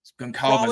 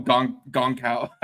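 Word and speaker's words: Gonçalves: Goncalves. Okay, I've been saying that like an idiot. I've been calling him Goncal. I didn't know. I'd never heard it out loud before. Okay, that's how you pronounce it.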